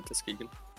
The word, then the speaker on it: Tuskegee: Pronounced Tuskegee? – Yeah, Tuskegee.